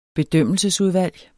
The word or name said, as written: bedømmelsesudvalg